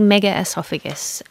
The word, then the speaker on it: megaesophagus: First of all, can you explain what this is and if I'm saying it correctly? – Yeah. So the condition's called megaesophagus.